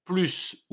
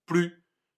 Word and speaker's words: plus: Cette fois, nous allons parler du mot plus ou plus. P-L-U-S. Les deux prononciations sont possibles et dépendent de la fonction et de la place du mot dans la phrase.